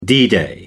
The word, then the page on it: d-day: American English